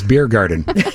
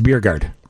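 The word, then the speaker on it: Bjerregaard: Oh, Lucas Biergarten. – Well, that's not how you say it. – No, it's Biergard. Lucas Biergard.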